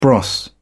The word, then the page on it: bros.: British English